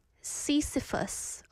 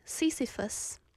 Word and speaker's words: Sisyphus: It's the myth of, how do I say that? I just did something wrong on the computer. No, why am I seeing numbers? Sisyphus pronunciation. It's not going to tell me now because I made a typo. I wrote Sisyphus. Okay. Sisyphus. Oh, I was right. Sisyphus.